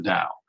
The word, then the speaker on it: Tao: And it's spelled T-A-O, but pronounced like a D, Tao.